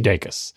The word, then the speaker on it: Dacus: I can affirmatively say that he pronounces his name day like the unit of time and cuss like you were saying a swear word. So Lucy Dacus.